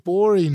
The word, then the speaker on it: Gborie: David Bory is here. Cool Guy Jokes 87 on Instagram. The G is silent on Twitter.